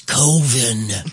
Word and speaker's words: Coven: which it's spelled Coven, but he's like, no, it's Coven.